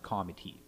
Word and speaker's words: committee: If anyone does any research on it, it's actually spelt committee, but it's pronounced comitee.